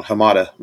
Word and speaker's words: Hamada: Walter Hammond has stepped down as the head of DC Films. At I might have said his last name wrong. Hamada. Walter Hamada has stepped down.